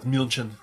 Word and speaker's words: München: How come it's München. Not? How come they don't pronounce it Munich? – It's München. Yeah, I don't know why we call it Munich.